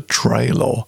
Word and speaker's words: Trailor: From an album Man Child, Herbie Hancock, and a track called The Trailer. And that's Trey, I pronounce it that way because it's spelt T-R-A-I-L-O-R.